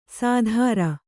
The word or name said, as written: ಸಾಧಾರ